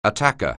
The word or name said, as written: attacker